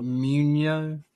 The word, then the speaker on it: Muino: M-U-I-N-O. – With a little. – Muino.